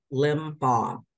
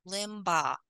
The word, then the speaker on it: Limbach: There are, I've heard it said so many different ways, but the true way to say it is Limbaugh. C-H is silent. – Limbaugh.